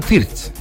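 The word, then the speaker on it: Wirtz: Leroy Sané, Jamel Musiala, Florian Wirt, no, es Florian Fierz. Florian Fierz, así se pronuncia.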